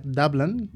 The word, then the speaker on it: Dublin: La seva capital és Dublín, com ja sabreu, que per cert en anglès es pronuncia Dublin, per si de cas, i els idiomes oficials són l'anglès i l'irlandès.